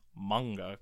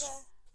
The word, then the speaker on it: manga: It's manga. – Well, us Americans like to say manga, but it's manga because it's a Japanese word. They have ma-ga. Manga.